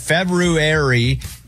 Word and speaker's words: February: But February is how it's said. Most Americans don't say it right. Both work are socially acceptable, but the R is how you would say it, February.